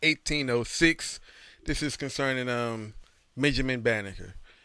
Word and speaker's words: Banneker: We call it Banneker. That's how you say it.